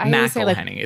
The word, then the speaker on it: McElhenney: McElhenney. – It's M-A... I want to say, like, it's spelled M-C, but it's pronounced M-A-C.